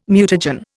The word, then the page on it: mutagen: Indian